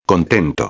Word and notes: contento: espanhol

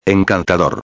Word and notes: encantador: espanhol

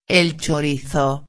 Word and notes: el chorizo: espanhol

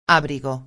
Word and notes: abrigo: espanhol

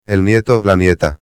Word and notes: el nieto-a: espanhol

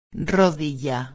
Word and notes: rodilla: espanhol

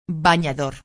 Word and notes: banador: espanhol

